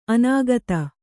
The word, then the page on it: ಅನಾಗತ: anāgata